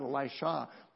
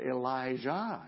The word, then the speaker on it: Elijah: And his name was, I said it wrong in the bulletin this morning, I apologize, it's not Elisha, but Elijah.